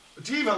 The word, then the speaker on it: Diva: Deva, like an I. Like a lady who's very important. Yeah. Or thinks she is. Mariah Carey, right? – Yeah. – Yeah, okay. Maybe it's a variation. Over here, the Italian way we spell it with D-I-V-A.